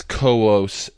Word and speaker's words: Coeus: That's Koos and Phoebe. Sometimes Koos is spelled C-O-E-U-S.